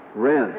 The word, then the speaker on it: Horan: Is it Horan? Wren. – As if the H isn't there.